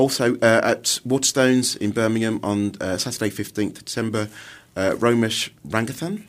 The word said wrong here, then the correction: Ranganathan: Ranganathan, I think. – Sorry, I was one syllable out.